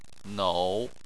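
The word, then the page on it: nou: tone 3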